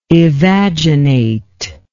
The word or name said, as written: evaginate